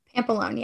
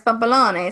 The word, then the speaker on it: Pampalone: Your last name is Pampalone. Is that how you pronounce it? – Pampalone, yeah. – Mm-hmm. Pampalone. Oh, so I should say it like I'm from Chicago. Pampalone. – Yeah. Well, if you want to say like you're from New York, it's Pampalone. So Pampalone.